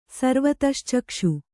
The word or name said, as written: ಸರ್ವತಶ್ಚಕ್ಷು